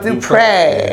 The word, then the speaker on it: Dupree: And put that accent on the E. – Yes, I'll be trying, y'all. Do pray. – There we go.